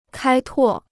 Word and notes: 开拓: kāi tuò

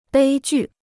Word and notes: 悲剧: bēi jù

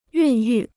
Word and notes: 孕育: yùn yù

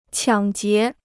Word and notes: 抢劫: qiǎng jié

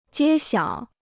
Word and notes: 揭晓: jiē xiǎo

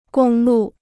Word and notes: 公路: gōng lù

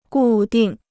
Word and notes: 固定: gù dìng

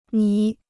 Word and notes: 迷: mí

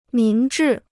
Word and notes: 明智: míng zhì

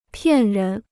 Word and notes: 骗人: piàn rén

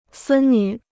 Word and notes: 孙女: sūn nǚ

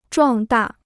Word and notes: 壮大: zhuàng dà

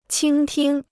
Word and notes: 倾听: qīng tīng

